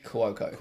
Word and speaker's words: Cuoco: How do you pronounce it? Kaylee Cuoco. – Cuoco.